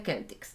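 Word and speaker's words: Celtx: Bueno, primero se pronuncia Celtics.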